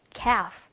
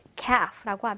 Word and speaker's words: calf: 자, 이 단어에서 이 L 사운드, L 사운드가 나지 않아요. 그래서 마치 L이 없는 것처럼 발음하시면 되고요. calf, calf라고 하면 송아지라는 뜻입니다.